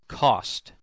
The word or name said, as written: cost